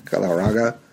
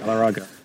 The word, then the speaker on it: Galarraga: Andreas Galarraga, right? Galarraga. That's how you say it. – Galarraga.